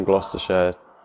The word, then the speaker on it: Gloucestershire: And obviously it's spelt with an O and U, which you'd look at and if you weren't from Gloucestershire, maybe you'd look at it as like Gloucestershire. – Yeah, you might. – Even the Shire, so like Gloucestershire, whereas I normally pronounce it Gloucestershire.